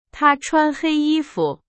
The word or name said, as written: 穿